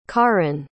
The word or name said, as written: kaaren